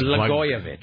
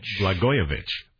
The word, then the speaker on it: Blagojevich: Disgraced Illinois Governor Rod Blug. Have we figured out how to say this guy's name yet? – It's Blagojevich. – Blagojevich.